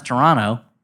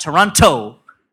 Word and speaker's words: Toronto: I learned how to pronounce Toronto. – All right. – Not Toronto, hitting that second T.